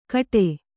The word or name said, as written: ಗೌಡಿ